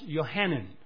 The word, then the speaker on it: Yohanan: John was his Hebrew name. It's pronounced Yohanan.